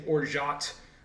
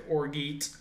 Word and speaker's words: orgeat: Well, first of all, if you're saying Orgeat, that also is it's French, right? So Orgeat. – Orgeat. Oh, so the hard T is not there.